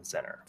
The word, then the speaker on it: Zennor: I think it's pronounced Zennor. Z-E-N-N-O-R. Yeah, Zennor.